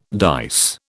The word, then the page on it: dice: British English